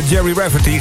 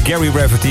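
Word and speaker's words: Gerry Rafferty: Jerry Rafferty, geen Gary Rafferty. Ik heb het zelf ook jaren verkeerd gezegd, maar Jerry is een afkorting van Gerald en daarom moet je het als Jerry uitspreken.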